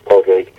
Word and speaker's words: Padraig: It would be Padraig. The Irish pronunciation of Patrick, Padraig.